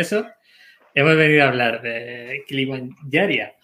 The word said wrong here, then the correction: Kilimanjaro: José, ¿cómo lo he dicho bien o mal? No, no, Kilimanjaria, Kilimanjaria. – Kilimanjaro.